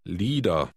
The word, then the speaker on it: Lieder: Lieder. – Note that the letter combination IE is pronounced E.